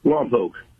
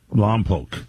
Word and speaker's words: Lompoc: Lompoc. – Lompoc. L-O-M is your lom, P-O-C, say poke, and you got it right.